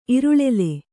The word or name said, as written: ಇರುಳೆಲೆ